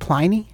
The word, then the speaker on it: Pliny: Apparently it's Pliny, folks. – So apparently our friend Pliny the Elder is actually Pliny the Elder. – But I think can we stick with Pliny? – I prefer Pliny.